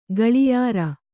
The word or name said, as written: ಘಳಿಯಾರ